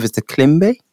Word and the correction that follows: kalimba: Kalimba, yeah.